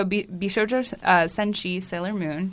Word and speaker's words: Bishoujo Senshi Sailor Moon: So Bishoujo Senshi Sailor Moon, did I say it right? – Yeah. Mm-hmm.